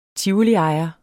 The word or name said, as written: tivoliejer